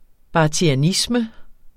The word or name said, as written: barthianisme